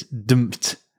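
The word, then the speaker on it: D'mt: It's dumped. It's spelled, I had to Google this to pronounce it D apostrophe M T. Dumped.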